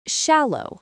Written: shallow

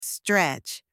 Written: stretch

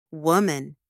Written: woman

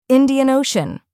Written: Indian Ocean

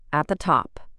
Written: at the top

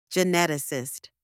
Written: geneticist

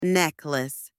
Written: necklace

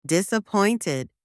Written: disappointed

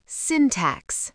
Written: syntax

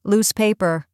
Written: loose paper

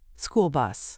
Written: school bus

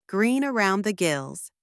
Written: green around the gills

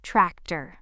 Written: tractor

